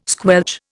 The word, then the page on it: squelch: Indian